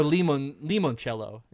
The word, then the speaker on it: Limoncello: Or Limoncello is how it's pronounced.